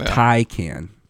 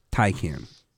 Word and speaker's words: Taycan: It's pronounced Taycan. Taycan.